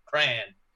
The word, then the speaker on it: Krahn: Brent Crayon, but not spelled like the fun Crayola Crayon, just K-R-A-H-N, Crayon.